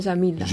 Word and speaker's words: Jamilla: Esta es la finca, se escribe Jamilla, pero le dicen Yamila.